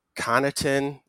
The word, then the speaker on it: Connaughton: And then, oh, gosh, I'm always going to get this name wrong. Pat Connaughton, Laz, you can correct me. I mean, he's an obvious. – That was right. That was good. – Is that right? Okay. I feel like I always want to pronounce the G somehow.